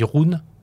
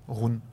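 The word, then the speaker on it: Rhûn: Euh, et le, j'avais noté aussi euh, l'inconnu Enori qui part, euh, qui vers le, alors on dit Rune, Rune. Rune. C'est tu vraiment jusqu'au au bout de la prononciation. – Il faut rouler les R.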